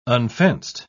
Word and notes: unfenced: British English

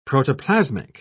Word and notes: protoplasmic: British English

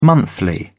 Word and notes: monthly: British English

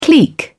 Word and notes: clique: British English

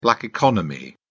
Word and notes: black economy: British English

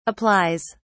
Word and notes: applies: British English

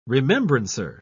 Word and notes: remembrancer: British English

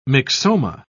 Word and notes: myxoma: British English

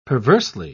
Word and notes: perversely: British English

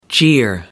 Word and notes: jeer: American English

